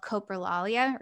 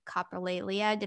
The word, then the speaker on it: coprolalia: I happen to have a more severe um, kind of version, there's not versions, but uh, of the condition, I have what's called coprolalia or coprolalia, depending on who you are and how you pronounce it.